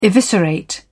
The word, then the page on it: eviscerate: British English